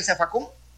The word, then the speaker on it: zafacón: Por ejemplo, cuando hablamos de zafacón, ¿dónde está la sílaba tónica ahí en zafacón? En la última sílaba, en la O. Por eso es una palabra aguda.